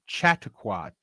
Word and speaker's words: Chautauqua: I had to look up a, a, a word because uh, oh, because I'd never heard it before, and the word is Chautauqua. Cha, ta, ta, Chautauqua. I'll spell it: C H A U T A UQUA.